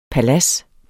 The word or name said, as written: PALADSER